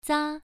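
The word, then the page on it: za: tone 1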